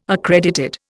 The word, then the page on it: accredited: Indian